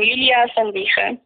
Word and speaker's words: Julia van Wichen: Ja, voor- en achternaam Julia van Wiggen.